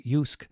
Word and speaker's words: JYSK: JYSK, pronounced Yusk, is well known for high quality, on-trend products for the home, from the bedroom to the garden.